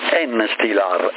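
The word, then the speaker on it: Enstilar: Sì, si pronuncia così, Instillar, ed è, principio attivo è calcipotriolo più betametasone, e sicuramente è un cortisone, è a base di cortisone.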